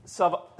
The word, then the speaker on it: Sabaoth: But the idea of hosts, Sabaoth, I believe is the way you'd pronounce that in the Hebrew, it's a Hebrew term which means a mass of persons, especially regularly organized for war, in parentheses, an army.